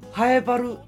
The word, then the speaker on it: 南風原: ハエバル」の漢字「南風原」と書いて何て読みますかっていう聞いたところ、えー、やっぱり難しいんですね「ハエバル」って、